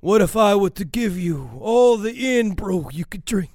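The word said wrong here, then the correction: Irn-Bru: It's pronounced what? Iron brew.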